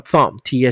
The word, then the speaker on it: thumb: 好，index finger 食指，OK，拉回让你看一下食指，大拇指叫 thumb，T H U M B，OK，T H U M B，B 不发音，那个食指叫 index finger，对吧？